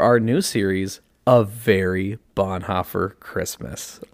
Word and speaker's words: Bonhoeffer: And you have to say it like that. Bonhoeffer. – That's right. – Because it's German. – Because it's German. So it's a little angry. A little angry in there. Mm-hmm.